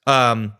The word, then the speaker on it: Gonzaga: And I'm thinking to myself, we can't view Gonzaga as Gonzaga. We're going to teach you how to say it before they win the national title.